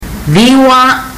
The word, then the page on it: ciwa: fiji